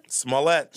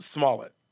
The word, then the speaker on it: Smollett: Wait, well, can we agree that his name is probably pronounced Smollett? – No, I say Smollett.